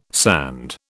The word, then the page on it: sand: British English